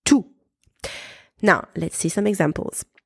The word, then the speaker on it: tous: T-O-U-S is pronounced tout when it is followed by a noun. And my little trick, if you can swap T-O-U-S with every,